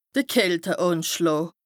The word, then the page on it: de Kälteànschlàà: Bas-Rhin; Strasbourg